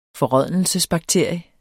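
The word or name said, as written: FORRÅDNELSESBAKTERIE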